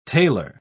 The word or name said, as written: tailor